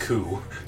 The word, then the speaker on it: coup: Well, it's pronounced coup.